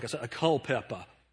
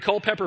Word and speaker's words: Culpeper: I always want to say it like a Culpeper. Culpeper, Virginia. So he was a preacher in Culpeper, Virginia.